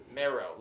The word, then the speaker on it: Mero: Mero. Like bone marrow.